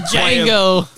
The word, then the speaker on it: Django: The D is silent, hillbilly. – To Django.